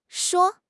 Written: shuo1

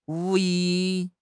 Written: 物业

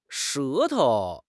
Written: 舌头

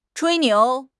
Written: 吹牛

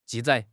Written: 记载

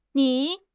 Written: ni3

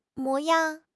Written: 模样

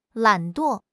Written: 懒惰

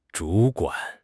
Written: 主管

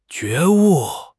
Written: 觉悟

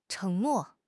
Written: 承诺

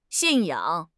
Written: 信仰

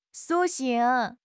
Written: 苏醒

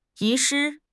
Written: 遗失

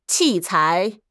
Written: 器材

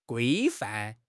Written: 规范

